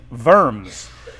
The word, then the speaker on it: Worms: And that council was called a diet, and it was held in Worms, is how you say it.